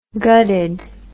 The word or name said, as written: gutted